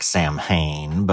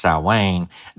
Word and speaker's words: Samhain: One of the kind of partial truths about Halloween is that it is based on an ancient Celtic festival called Samhain, spelled to look like Samhain, but pronounced Samhain, that was kind of a harvest in the summer festival.